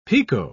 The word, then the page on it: pekoe: British English